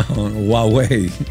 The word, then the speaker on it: Huawei: No, Huawei. – ¿Tú ves que yo no. – Huawei. – Oye, de la forma de cómo se escribe a cómo se pronuncia es muy diferente. – Sí, sí. Claro. – H-U-A.